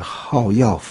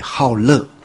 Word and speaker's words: 好乐: 那这个以前的人说念好药啊，这我觉得好药反而意思不明显了。对，好乐，喜欢嘛，对，所以实在是没什么道理，而且你念成好药，字典里面没有，这是我以前的这个学佛的人把它这个大家约定俗成这样子啊，约定俗成，但是这个约定哈，世上有没有约定？